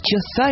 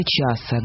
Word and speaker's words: часа: Два академических часа или часа? Где должны быть ударения? – Я бы сказала часа. Два академических часа, но мне кажется, что часа тоже не будет ошибкой.